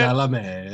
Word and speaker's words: Chalamet: Chalamet, yeah.